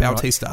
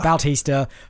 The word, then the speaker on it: Bautista: Bautista. – Right. Bautista for Tasmania.